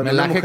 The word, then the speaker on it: Melaque: No sé si se dice así, güey, Melaque. Eh, Melaque que sí.